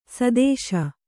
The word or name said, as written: ಸದಸ್ಸು